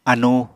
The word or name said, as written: anu